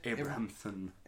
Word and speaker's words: Abrahamson: Um, Lenny Abraham's. Am I saying that right? – Abrahamson. – Abrahamson. Abrahamson. God, lovely Irish name that.